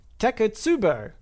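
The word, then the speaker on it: takotsubo: Okay, I'm going to assume that came to another pronunciation thing in another email, but Teko Tsubo is how you pronounce that.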